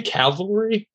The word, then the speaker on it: Cavalry: They'll probably kill me because I can't pronounce that word. Cavalry. There we go.